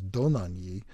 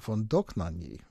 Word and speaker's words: Dohnányi: They do pronounce it different. And it's kind of an interesting story. The original Hungarian pronunciation apparently is Dohnányi, but the family moved to Germany and Germanized it to von Dohnányi.